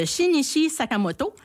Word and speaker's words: Shinichi Sakamoto: Donc, c'est une œuvre de Shinichi Sakamoto.